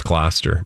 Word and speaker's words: Gloucester: It's not Gloucester, right? – No, it's Gloucester.